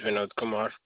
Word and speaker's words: Vinod Kumar: My name is Vinod Kumar.